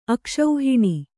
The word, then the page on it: ಅಕ್ಷೋಹಿಣಿ: akṣōhiṇi